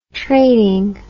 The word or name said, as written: trading